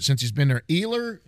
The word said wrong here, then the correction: Elier: Hernandez. – Elier. Elier Hernandez who came up.